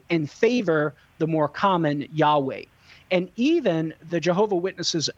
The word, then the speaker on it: Yahweh: Hebrew scholars generally favor Yahweh as the most likely pronunciation. So even the JWs affirm that general pronunciation, namely Yahweh.